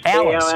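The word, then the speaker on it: West Allis: Alice.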